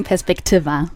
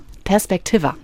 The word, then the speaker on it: Perspektiva: Und das Projekt, habe ich das richtig gesagt? – Fast. – Wie sagt man es richtig? – Wir sagen Perspektiva. – Perspektiva.